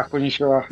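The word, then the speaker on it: коничева (こんにちは): А, как правильно говорить, коничева, да? Очень добрый. Да, коничева.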